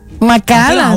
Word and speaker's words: Macallan: Macallan. – Ah, bueno, tú sabes de whisky entonces, porque esa es la pronunciación.